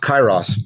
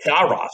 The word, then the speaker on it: Kairos: I've always heard it pronounced Kairos, but... – Kairos, okay.